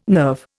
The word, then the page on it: nerve: Indian